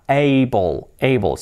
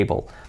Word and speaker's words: able: Able. Ser capaz de poder hacer algo. To be able. Able. Escrito hable y se pronuncia able. Ya. Yeah. Able. Con la L al final.